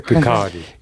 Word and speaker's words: Kirkcaldy: Se pronuncia Kirkcaldy.